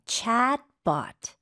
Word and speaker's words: chatbot: 챗가 아니고 A가 좀 더 긴 느낌이고 입을 더 벌려야 돼요. Chatbot. – chatbot. – Chatbot. – 네, 봇이 아니네요. – 맞아요. – 이것도 로봇처럼 BOT는 bot으로 발음을 하셔야 됩니다.